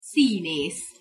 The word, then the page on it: szinesz: Hungarian